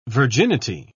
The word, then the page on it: virginity: British English